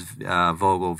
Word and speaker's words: Vogel: And then last name is Vogel, V as in Victor, O-G-E-L.